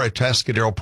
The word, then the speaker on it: Atascadero: He is the director and the president of We Are Atascadero Pride.